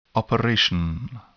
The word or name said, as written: operation